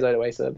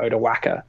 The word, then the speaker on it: Odoacer: she was like, How do you pronounce it? Odawesa? And I'm like, Yeah, it is Odawesa. But then also some people call it Odawaka.